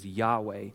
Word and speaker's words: Yahweh: That's Yud, He, Vav, He, what some people would say is pronounced as Yahweh.